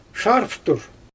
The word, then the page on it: ʃarvtur: Xyzyl